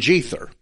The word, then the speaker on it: Jether: The letter J used to be pronounced as the letter Y. So it would be Jether, which is a lot closer to Ithra.